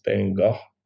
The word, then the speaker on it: Van Gogh: So when the Van Gogh we know was born, and by the way, that's not how people pronounce his name in Europe. It's Van Gogh, a little more G in that Van Gogh.